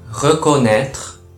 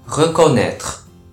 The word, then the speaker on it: reconnaître: reconnaître, reconnaître と発音します。